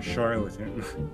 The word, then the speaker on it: charlatan: One of the things that I read, it was said he's not a doctor, he's a charlatan. I believe that's how you say it, right? – How you say it. You're saying it right.